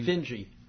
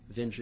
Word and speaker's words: Vinge: Vinge, by the way. – Oh, is that how you say it? – Vinge, yeah.